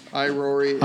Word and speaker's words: Irori: Erori, is that how you pronounce it? – Irori.